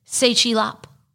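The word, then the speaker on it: Tse Chi Lop: I don't know how to pronounce T-S-E. – Say. – Say? Sechilop. That's his name. – Sechilop.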